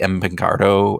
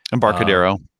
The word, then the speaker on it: Embarcadero: Embarcadero. I always pronounce that wrong. – Embarcadero. – Embarcadero.